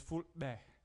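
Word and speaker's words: Fulbe: Well, the way they say it is Futbe. Futbe. – Repeat after me, kids. Futbe. – And that means people. – Why do we say Fulani? Is it because it's phonetically spelled Fulani? – Fulani is just the way English people call them.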